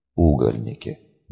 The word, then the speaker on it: угольники: Угольники. Надо же. Постановка ударения на первый слог придавала слову необычное и яркое звучание. Лучшего названия этим бестиям и не дашь.